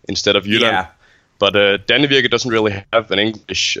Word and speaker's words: Danevirke: Okay, so in Danish, it's called Danevirke.